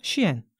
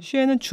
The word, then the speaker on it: SHEIN: 오늘 사실은 테무 말고 또 하나 지윤 박사님과 얘기하고 싶은 곳이 의류 쇼핑 플랫폼 쉬엔. 스펠링은 SHEIN. – 근데 그걸 쉬엔이라고 읽어? – 그 쉬엔 같기도 하고 쉐인 같기도 하고 중국 남방인데 일반적으로는 쉬엔이라고 부르더라고요.